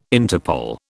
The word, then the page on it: Interpol: British English